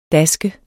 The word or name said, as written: daske